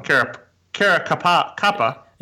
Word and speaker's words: Caracappa: He teamed up with famous mob lawyer Bruce Cutler to represent Louis Ippolito and Stefan Caracappola. I said that wrong. Caracappa.